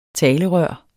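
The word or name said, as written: TALERØRENES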